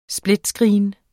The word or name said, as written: split